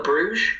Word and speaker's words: Bruges: Club Bruges? – Club Bruges.